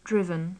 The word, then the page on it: driven: English